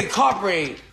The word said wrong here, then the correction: DiCaprio: Leonardo DiCaprio. – Ain't no T in there.